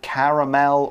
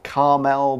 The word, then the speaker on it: caramel: Yeah, caramel, and you can pronounce it caramel or caramel, both are okay.